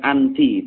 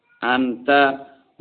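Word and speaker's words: anta (أنتَ): Jadi bacanya anta, anta, bukan anti, tapi anta. Ta-nya di fathah.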